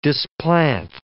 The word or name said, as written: displant